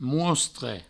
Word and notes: mostre: mouostre

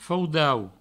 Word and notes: faudau: Provençal

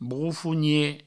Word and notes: brofonie: broufounie

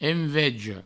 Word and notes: enveja: envejo; provençal

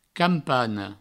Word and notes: campana: provençal; campano

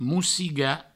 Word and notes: mossigar: moussiga

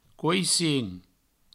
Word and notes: coissin: couissin

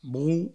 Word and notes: brot: brout

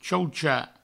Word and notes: chauchar: chaucha; provençal

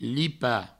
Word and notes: lipar: provençal; lipa

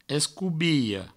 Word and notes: escobilha: provençal; escoubiho

